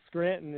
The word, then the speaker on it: Scranton: And then Scranton is spelled S-C-R-A-N-T-O-N. It's just like the city in Pennsylvania.